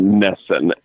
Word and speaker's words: NESN: But yes, it's said how it sounds, NESN, N-E-S-N.